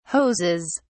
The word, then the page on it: hoses: British English